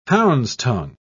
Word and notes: hound's-tongue: British English

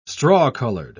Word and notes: straw-coloured: British English

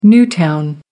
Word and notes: new town: American English

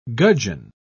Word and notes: gudgeon: British English

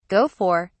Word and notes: go for: British English

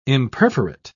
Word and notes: imperforate: British English

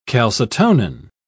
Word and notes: calcitonin: British English

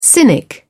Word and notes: cynic: British English

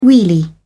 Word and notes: wheelie: British English

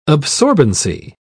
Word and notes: absorbency: British English

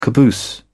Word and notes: caboose: British English

